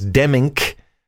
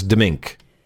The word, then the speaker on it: Demmink: His name is Demmink, although it is here pronounced as Demink.